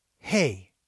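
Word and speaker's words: He (ה): And it is He. It's like the letter H. And it's pronounced like hey. There's a breath coming out.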